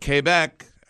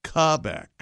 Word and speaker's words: Quebec: Please let me correct your pronunciation of the province of Quebec. – I say Quebec because I'm an American. – Quebec. – It's Quebec. – Instead of Quebec please inform her that it should be pronounced Quebec. – Oh. – As in the Grand High Poobah of International Windbags, Alex Trebek might say, makes sense, doesn't it? – Quebec.